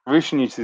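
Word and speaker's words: Višnjić: Oh, yeah, Goran Visnjic, right? Yeah. – Visnjic is how we pronounce it.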